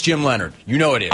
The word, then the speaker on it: Leonhard: There are a lot of names that are not pronounced the way they are spelled, including Jim Leonhard.